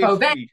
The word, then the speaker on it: Fauve: So that's why I've been asking before the show, is it pronounced Fauve? Is it Fauve? – Fauve. And I'm like, oh.